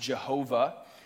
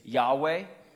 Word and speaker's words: Yahweh: You might have grown up in church hearing it pronounced Jehovah. And now most Bible scholars say that's probably not right. That's actually probably a German pronunciation of the Latin transliteration of YHWH. Probably the most accurate translation of God's name or pronunciation, I should say, is Yahweh or something like that.